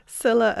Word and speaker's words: Scylla: During the new featured episode, Sia and Charybdis. – Scylla and Charybdis.